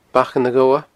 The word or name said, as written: Bacan a' Ghobha